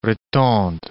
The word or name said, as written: Rethondes